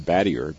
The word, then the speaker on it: Battier: So all of his records got changed to Battier, B-A-T-T-I-E-R. And he met my mother in 76. They got married. And she said, it looks French. We're going to pronounce it Battier.